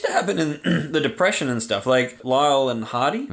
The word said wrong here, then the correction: Laurel: Is that how you say it, Lyle? – Laurel and Hardy.